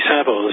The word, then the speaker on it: Savile: Yeah, well, Jimmy Savile, people in the States won't necessarily know this, but, I mean, many of us that grew up in the 60s and 70s remember Jimmy Savile as this sort of friendly face of pop culture on the TV.